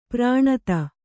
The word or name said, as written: ಪ್ರಾಣತ